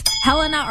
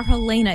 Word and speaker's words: Helena: Yes, Helena or Helena is correct. Both are actually accepted pronunciations.